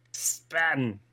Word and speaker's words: Spaten: It's Spaten, like you're from Boston. – Oh. Yeah. Spaten.